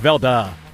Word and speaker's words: Velde: By the way, you're saying the Timber name wrong. – Christopher Velde. – No, it's Velda. – Okay. – That's according to Timber Patrick. – Timber Patrick is saying. – It's like, dude, it's Velda, not Velda.